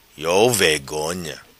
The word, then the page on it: Vegonha Iou: patua